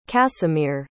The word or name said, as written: casimir